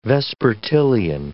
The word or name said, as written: vespertilian